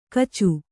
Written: ಕಾಚು

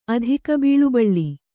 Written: ಅಧಿಕಬೀಳುಬಳ್ಳಿ

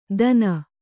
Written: ದನ

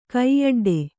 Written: ಕೈಯೊಡ್ಡು

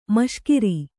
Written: ಮೀಸೆಗಾರ

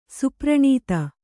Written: ಸುಪ್ರಣೀತ

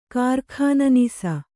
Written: ಕಾರ್ಖಾನನೀಸ

